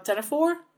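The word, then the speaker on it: ctenophore: This is one word that we have another silent letter word. What's the other word for a comb jelly, which I'm sure you know a lot about? – Oh, tenafor? – Yeah.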